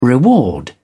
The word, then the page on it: reward: British English